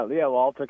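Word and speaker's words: L'Alto: Am I pronouncing that correctly? – Yeah, Lalto Catholic Institute, from Blessed Pier Giorgio Frassati's famous Verso Lalto quote, to the heights.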